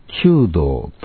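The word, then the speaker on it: 求道: ところでキリスト教では弓道と読みます。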